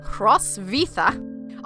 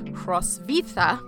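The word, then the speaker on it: Hrotsvitha: But the Latinized pronunciation of her name is Hrosvita. – All right. – And she wrote in Latin and wrote her name that way. So that's how we're going to pronounce her name today.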